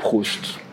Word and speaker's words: Proust: Now, one of the inventive things that you've done also is, and I know I'm going to butcher the name, is the Proust questionnaire. – I love you, I know. Proust.